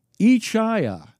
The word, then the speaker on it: Ichaya: She wanted it to be pronounced Ichaya. And in the script, it's spelled I-C-H-A-Y-A.